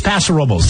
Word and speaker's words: Paso Robles: and San Francisco, no offense to y'all, but you just say it wrong. It's Paso Robles. That's how the locals say it, Paso Robles.